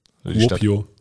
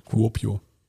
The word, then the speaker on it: Kuopio: Kuopio. Kuopio. So spricht man das, glaube ich, aus. – Ja, ist gut gemacht.